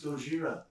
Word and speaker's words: Gojira: I was like, it's pronounced Gojira.